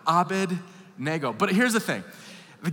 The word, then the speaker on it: Abednego: By the way, when I was a kid, I was taught to pronounce it Abednego. That's not how it's spelled. It's Abednego.